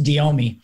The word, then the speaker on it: DEOMI: And that whole big mouthful, you can condense down to an acronym pronounced DEOMI.